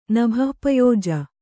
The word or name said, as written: ನಭಃಪಯೋಜ